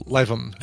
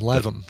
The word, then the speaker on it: Lytham: Livam. – L-Y-T-H-A-M is spelled Livam. – It's pronounced L-I-V-E dash U-M, Livam.